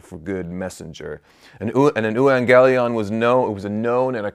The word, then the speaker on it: euangelion: It's euangelion, that's how you pronounce that,